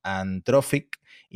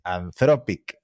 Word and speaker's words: Anthropic: Lo que sí tengo que decir, que no tiene nada que ver con la noticia, es que nos han pedido que pronunciemos bien Anthropic, porque siempre decimos Anthropic y es Anthropic.